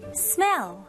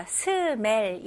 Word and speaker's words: smell: 네, 발음은 일단 이름절로 해주셔야 돼요. 그래서 smell 이런 식 아니고요. smell. smell. Okay, very good.